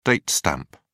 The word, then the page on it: date stamp: British English